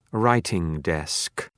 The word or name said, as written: writing-desk